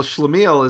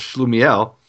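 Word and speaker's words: schlemiel: Well, Shlameel is their pronunciation of Shlumiel, and Shlamazel is Shlumiel's friend.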